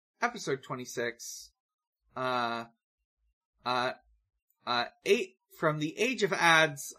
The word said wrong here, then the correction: Odds: title track. – Age of Odds. – Age of Odds, sorry. – It rhymes with pods.